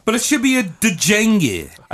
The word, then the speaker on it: Django: Django Reinhardt starts with a D, believe it or not. D-J-A-N-G-O.